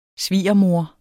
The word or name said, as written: svigermor